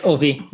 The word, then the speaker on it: Ovi: Yeah, it's Ovi, but uh, that was close enough.